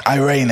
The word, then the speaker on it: eirene: Irene, it's spelled like Irene. E-I-R-E-N-E.